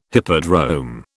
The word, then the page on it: hippodrome: British English